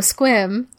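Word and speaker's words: Sequim: So if any of the listeners know Squim. – That's the cutest sounding town. – It's spelled like sequin, but with an M at the end.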